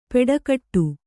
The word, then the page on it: ಪೆಡಗಟ್ಟು: kannada